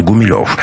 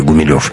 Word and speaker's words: Гумилев: Сам Николай Степанович из семьи провинциальных приходских священников, о чем более чем красноречиво свидетельствует сама фамилия Гумилев. Кстати, знаете ли вы, что в те времена ударение в этой фамилии ставили на первый слог, поскольку фамилия эта происходила от латинского слова «humilis» — «смиренный».